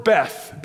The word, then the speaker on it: Beth: The word Bethel, the word Beth, B-E-T-H, really it's pronounced Hebrew, Bet, all right, Bet.